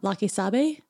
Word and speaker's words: La Que Sabe: La Que Sabe. Am I saying that right? Mm-hmm.